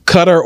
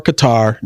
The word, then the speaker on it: Qatar: So, Qatar or Qatar, depending upon what you want to say. You know, Americans say it one way. They say it another way in their region.